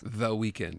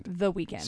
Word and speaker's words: The Weeknd: The Weeknd. The Weeknd. Spelled without an E before kind. – Yeah, it's right, ND. Okay.